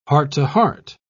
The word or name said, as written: heart-to-heart